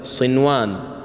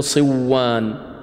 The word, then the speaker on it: صنوان: وأيضا لفظ صنوان لا تقول صوان، وكذلك قنوان ما نقول قوان، وكذلك بنيان لا تقول بيان، لأن المعنى سيختلف لأجل اختلاف المعنى، ولأن العرب عندما تنطق بهذه الكلمات لا تدغم النون الساكنة في حرف الإدغام.